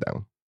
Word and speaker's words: doux: write it down. Let's start with do. Do. – Okay, so I'm going to write down what I think I hear. And I'm going to say that this is spelled D-O-U-X.